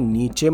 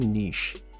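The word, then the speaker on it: niche: इसको देखिए नीचे मत बोलिएगा ये एक्चुअली प्रोनाउंस होता है नीश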